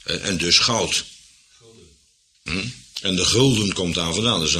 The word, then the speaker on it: gulden: Het zal een gouden florijn geweest zijn, vandaar dat wij nog een F schrijven en dan uitspreken, gulden.